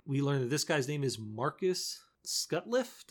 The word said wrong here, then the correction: Sutcliff: Did I say that right? – Sutcliff, thank you.